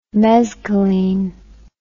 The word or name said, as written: mezcaline